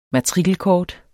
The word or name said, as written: matrikelkort